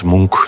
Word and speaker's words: Munch: Edvard Munch, zo moeten we het uitspreken.